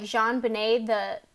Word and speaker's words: JonBenét: Her name is pronounced in the French style, though, so it sounds like JonBenét. The J at the beginning is a little softer.